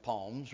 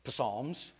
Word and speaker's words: Psalms: That's like somebody mispronouncing the word Job and calling it Job, or Psalms and calling it Palms or whatever, Psalms, you know.